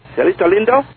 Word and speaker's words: Cielito Lindo: Cielito Lindo? – That's right.